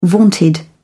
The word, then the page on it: vaunted: British English